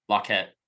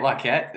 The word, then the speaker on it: Lockett: He You say it Laquette? Is that how you actually pronounce it? Or is that how it's supposed to be pronounced? – That's how I say it. I might be saying it incorrectly. That's just how I say it. – Okay. I'm pretty sure it's Lockett, but it's a small thing.